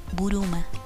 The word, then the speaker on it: Bulma: sabemos que Bulma se pronuncia eh, Buruma, que en japonés significa bragas.